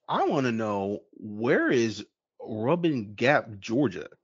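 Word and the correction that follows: Rabun Gap: Rabin Gap is how it's pronounced.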